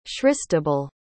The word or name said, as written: christabelle